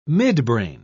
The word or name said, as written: midbrain